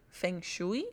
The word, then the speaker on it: Feng Shui: Feng Shui, se pronuncia así.